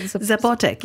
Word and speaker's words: Zapotec: Other societies that were studied included the Aztecs, the Mayans, the Zapotec civilization. Is that how you pronounce that? – Zapotec, yeah.